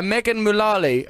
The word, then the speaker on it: Megan Mullally: Hader was discovered by Megan Mullally of, is that the right pronunciation? – Yeah.